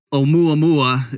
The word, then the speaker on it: Oumuamua: Oumuamua. Is that a decent pronunciation? – Yes, that's perfect.